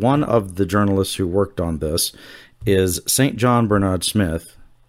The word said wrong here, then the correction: St. John: He'll ding me for saying his name wrong. He says his name's Sinjin, which I'm not sure how he gets to that.